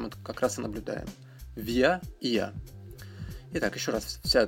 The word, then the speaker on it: wir: По поводу произношения местоимений «виа» и ия мы «вы» люди, которые учили немецкий в школе, часто говорят, что вот нас учили говорить просто «вир», «ир». Но, ну, как вы понимаете, в школах не всегда э, уделяют внимание лучшему варианту произношения, поэтому на самом деле должны эти местоимения произноситься именно так via, «я».